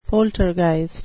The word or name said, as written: poltergeist